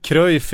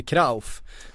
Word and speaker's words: Cruyff: När vi har sagt Cruyff i 40 år, han egentligen heter Krauf.